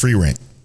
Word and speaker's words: Frieren: It's pronounced Frieren.